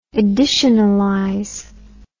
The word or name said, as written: editionalize